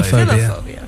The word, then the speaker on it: Philophobia: Philophobia. Philophobia.